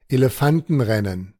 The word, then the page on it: Elefantenrennen: German